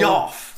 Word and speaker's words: Geoff: So Geoff. – For years, I was like, Geoff.